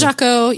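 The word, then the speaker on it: Jacot: Jaco,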